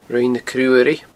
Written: Roinn na Cruaruigh

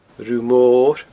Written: Rubha Mor

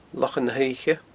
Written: Lochan na h-Oidhche

